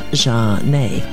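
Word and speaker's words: Zhané: Sending My Love by Jeanne, a single from their album entitled Pronounced Jeanne, released in 1994. Incidentally, the spelling of their name is Z-H-A-N-E.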